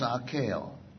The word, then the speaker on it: Akeo: My real name is Akeo. Very simple, four letters, A-K-E-O.